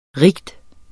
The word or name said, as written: rige